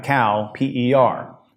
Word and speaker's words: Cowper: It's C-O-W, like cow, P-E-R.